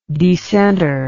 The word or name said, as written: decentre